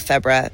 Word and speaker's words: Febra: And his wife also came out, Febra, who I said her name wrong like 8 times. Deborah with an F, Febra, and she's wonderful.